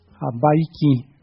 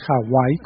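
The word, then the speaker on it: Habaiki (Hawaiʻi): Y acá seguimos tras escuchar esta música deliciosa de la señora Taimane Gardner Habaiki. Fíjense, ya nos enseñó a pronunciar mejor, no es Hawái como nos dice el imperio, sino Habaiki.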